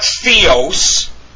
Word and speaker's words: Theos: It's not Theos. Theos is gibberish. Theos is how you pronounce it. Theos. Not Theos. Not Theos. Not Theos. Theos. That is the correct pronunciation of that form.